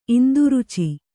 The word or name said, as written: ಇಂದುರುಚಿ